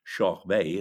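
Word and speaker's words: Körberg: Murray Head, Elaine Paige, and Tommy Korberg, or Shoch Bay" as he's pronounced in Sweden,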